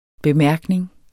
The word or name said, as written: bemærkning